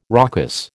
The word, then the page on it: raucous: American English